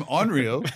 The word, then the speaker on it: onryo: Onryo. – It's like, let's spell it. – O-N-R-Y-O.